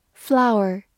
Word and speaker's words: flour: Flour is sold by kilogram. Flour is sold by kilogram. 注意一下，它有个同音词 f l o w e r，也读作 flower，名词花或者动词开花。